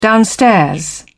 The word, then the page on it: downstairs: British English